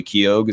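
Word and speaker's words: Keough: And like there's something, the writer that she enlists, which the mother is played by Riley Keogh. Is that how you say her name? – I believe so.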